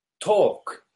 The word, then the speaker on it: talk: Por eso cuando en inglés se utiliza la palabra hablar, se dice talk. Talk. Mira cuánto es muy, muy, muy extraño la lengua, la lengua inglesa. Talk se, se pronuncia talk se escribe así, pero se pronuncia así.